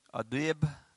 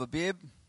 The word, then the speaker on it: Abib: Abib. It's kind of hard to pronounce. A-B-I-B.